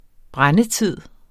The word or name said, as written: brændetid